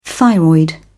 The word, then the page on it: thyroid: British English